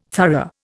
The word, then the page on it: thorough: Indian